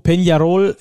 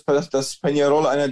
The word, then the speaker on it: Peñarol: Dein Club Atlético Peñarol, ich hoffe, ich habe das richtig ausgesprochen mit diesem Enye, Peñarol, genau, hat ja eine 100-jährige Fußballgeschichte oder eine Clubgeschichte im Fußball vor allem.